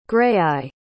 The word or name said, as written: graeae